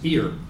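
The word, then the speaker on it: Iyar: By the way, the second month is pronounced ear. In our English, I-Y-E-R, ear, which is the combination of part of April, part of May.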